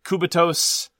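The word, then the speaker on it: Cubitos: And then you and I played together, I'm going to pronounce it this way, as in like a operating system. – Okay. – Qubit OS. – I've heard it pronounced a number of different ways. Qubitos, Qubitos. Cubit. I like Qubitos because it's about throwing lots of dice.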